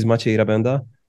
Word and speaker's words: Maciej Rabęda: So my name is Maciej Rabenda.